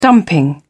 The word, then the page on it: dumping: British English